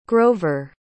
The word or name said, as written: grover